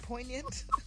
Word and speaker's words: poignant: One last attempt there by poignant. – Poignant. Yeah, there's a nasty little silent G in there.